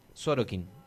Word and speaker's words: Sorokin: La Sole va a estar cerrando mañana justamente este festival y en el día jueves que comenzó estuvo Coti Sorosink. – Vi fotos de, de gentes que, que se sacaron con el artista. – Sorokin. Ahí lo dije bien.